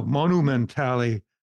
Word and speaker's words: Monumentale: And uh, uh, Marco took up took his camera and visited the Monumentale no Monumentale Cemetery of Milan. He's he's helping me pronounce Italian properly it's hard for hard for a gringo, you know, especially a hillbilly, to uh, pronounce their language properly.